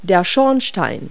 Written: funnel

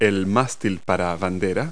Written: flagpole

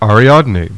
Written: ARIADNE

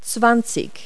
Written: twenty

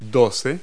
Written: twelve

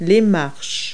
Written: steps

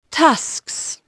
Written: tusks

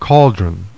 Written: CAULDRON

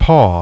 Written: PAW

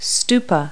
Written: stupa

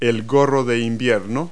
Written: toque

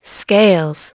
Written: scales

